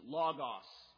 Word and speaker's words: logos: The Greek word behind that, depending on your pronunciation, I pronounce my Omicrons this way, is logos.